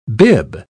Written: bib

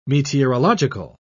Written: meteorological

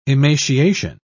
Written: emaciation

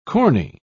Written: corny

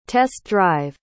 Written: test-drive

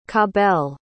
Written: Cabell

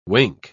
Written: wink

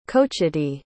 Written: cochiti

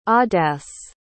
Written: audace

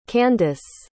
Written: Kandice

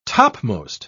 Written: topmost

